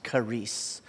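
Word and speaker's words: charis (χάρις): So the word grace in the original language that Paul would have written in is a Greek word that's pronounced charis. Charis is how you would transliterate it in English.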